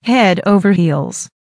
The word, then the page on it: head over heels: American English